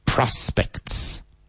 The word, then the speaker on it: prospects: Spelled I-N-F-L-U-E-N-T-I-A-L, but influential. Shul. Préstamo. Loan. Hogar de ancianos. Nursing home. Perspectivas. Prospects. Emphasis on the first syllable. Prospects.